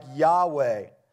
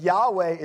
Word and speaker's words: Yahweh: And it may have been pronounced something like Yahweh.